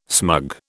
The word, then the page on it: smug: British English